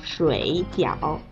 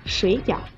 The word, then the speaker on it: 水饺: 第一个字往往会比较难发，你听，水饺，所以啊，第一个字会有变调，变成二声，水饺就会读成二声和三声啦，就是水饺。